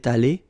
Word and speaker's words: allée: Phonetically, it doesn't exist. You don't pronounce it, so you get elle est allée, and then for the masculine form, il est allé. So aller is pronounced the same way.